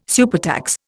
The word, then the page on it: supertax: Indian